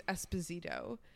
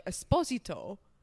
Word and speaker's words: Esposito: my grandfather, my mom's father, their last name is Esposito, but mm-hmm. I guess the Italian pronunciation was more like Esposito, and whoever was writing the census spelled it like A-S-P-O, you know, and the rest of it instead of E-S.